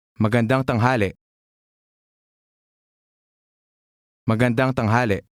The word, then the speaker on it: Magandang: Note the stress on the last syllable. Listen and repeat again. – Magandang.